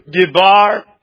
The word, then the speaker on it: dabar: Even the noun for word, it's spelled D-A-B-A-R in Hebrew, Dibar.